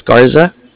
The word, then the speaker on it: Garza: O-N-S-O. And then my last name is Garza. – How do you spell that? – Yeah, G-A-R-Z-A.